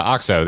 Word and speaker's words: OXO: Yeah, OXO.